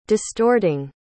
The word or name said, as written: distorting